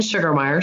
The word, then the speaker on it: Sugermeyer: So Jen Sugermeyer, sugar is spelled with an E.